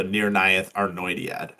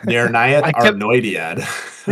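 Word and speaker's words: Nirnaeth Arnoediad: So this is it's after that, and it's leading up to the Battle of Unnumbered Tears, or the Nir-Nayeth Arnoidiad. – Dang, that was really good, like, pronunciation. – Nir-Nayeth Arnoidiad.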